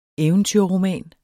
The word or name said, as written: eventyrroman